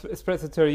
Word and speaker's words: sprezzatura: It's this idea of, I don't know how to pronounce it, but sprezzatura. – Yes, sprezzatura. You pronounced it correct, I think.